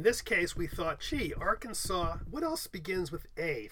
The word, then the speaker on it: Arkansas: should be spelled Arkansas, but pronounced Arkansas.